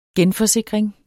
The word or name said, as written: GENFORSIKRING